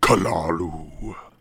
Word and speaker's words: Cthulhu: Not to mention the beginnings, the outline of what would become The Call of Kalalu. – Wait, is that how it's actually pronounced? – According to Lovecraft himself, it is, dear boy. It is meant to be a guttural sounding name.